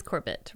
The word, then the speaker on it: Corbett: Corbett. I kept saying Corbin. Yeah. – Mm-hmm. Oh, Corbin. – No, I think it is Corbett, right? – I mean, unless it's pronounced weird. – I think I was pronouncing it wrong.